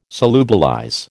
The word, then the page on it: solubilize: American English